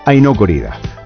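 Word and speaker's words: Ai no Corrida: Bueno, y el título en japonés se pronuncia como el título de este tema, que es Aino Korida.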